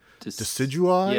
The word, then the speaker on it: Decidueye: So Rowlet turns into, I don't even know how to pronounce this character's name, Decidueye? – Yeah, that sounds right.